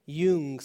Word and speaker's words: Jung: So the psychiatrist Carl Jung, or Jung, I don't know how you pronounce that, Jung. How? Jung, sorry.